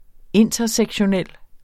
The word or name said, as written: intersektionel